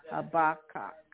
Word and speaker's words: Habakkuk: It is pronounced Habakkuk.